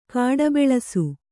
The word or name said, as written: ಕಾಡಬೆಳಸು